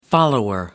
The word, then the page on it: follower: American English